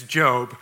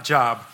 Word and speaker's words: Job: We say his name is Job, not Job.